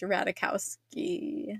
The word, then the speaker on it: Ratajkowski: I think it is Emily, and I think it's pronounced Ratajkowski. – Let's look it up. I actually have a very personal connection to Miss Emrata. – Yes, because... Okay, her name is Emily Ratajkowski. – I don't think unless the J is pronounced.